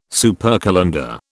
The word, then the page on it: supercalender: British English